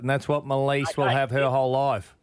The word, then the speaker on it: Maleese: So she spelled it M-A-L-E-E-S-E, as you would say it, Melise.